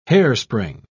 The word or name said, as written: hairspring